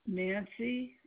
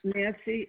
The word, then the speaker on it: Nancy: Nancy Nicodemo.